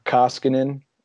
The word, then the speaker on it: Koskinen: So Koskinen, I believe how you pronounce it, right? – Yep.